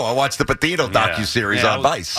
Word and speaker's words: Petito: Gabby Petrino? How do you say her name? – It's Petito. – Petito. Gabby Petito.